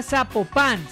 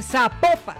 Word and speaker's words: Zapopan: Nos escriben los oyentes y que no se, no se pronuncia Zapopan, sino que se pronuncia Zapopan.